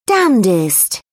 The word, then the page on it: damnedest: British English